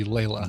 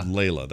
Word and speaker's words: Leila: When I initially read the Facebook post, I thought it was Leia, but that I would pronounce L-E-I-L-A would be Layla. – Layla.